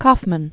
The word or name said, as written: Kaufman